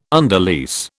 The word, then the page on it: underlease: British English